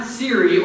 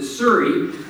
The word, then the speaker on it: Siri: She had just gotten Siri, or as she pronounces it, Suri, going. And my mom was a third grade teacher, excellent reader. S-I-R-I, Suri.